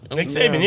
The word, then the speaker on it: Nick Saban: Nick Saban, yeah.